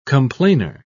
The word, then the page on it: complainer: British English